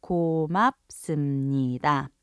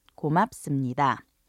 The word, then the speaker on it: 고맙습니다: Once again, it's pronounced 고맙습니다. 고맙습니다.